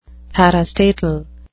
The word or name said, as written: parastatal